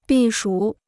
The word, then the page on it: 避暑: bì shǔ